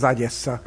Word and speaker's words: Odessa: A kiejtés szerint az Agyessa lenne hivatalos, de hát nyilván itt egy ukrán szakértő kellene, révén itt egy ukrán városról van szó, tengerpart, nagyon szép tengerparti város.